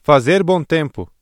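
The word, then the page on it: fazer bom tempo: Brazilian Portuguese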